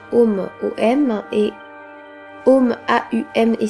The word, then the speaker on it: AUM: Euh, AUM, en fait, c'est OM, concrètement. Je sais que le son om euh, aujourd'hui on l'écrit OM, mais traditionnellement ça s'écrit AUM et silence.